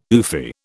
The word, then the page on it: oofy: British English